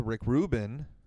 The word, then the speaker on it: Rick Rubin: And then they run into Rick Rubin.